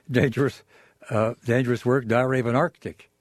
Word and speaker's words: Arctic: My guest tonight is John Lellenberg, who's co-editor of Dangerous Work, Diary of an Arctic Adventurer. Do you require yourself to say Arctic? Do you give the middle C pronunciation? – Always.